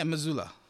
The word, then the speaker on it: Mazzulla: I don't know how to say his last name. I think it's... – Yeah, Mazzulla.